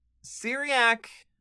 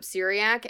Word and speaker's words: Cyriak: So, Syriac, and I will get the spelling very quickly here. So, C-Y-R-I-A-K, Syriac, is the best pronunciation that I can assume.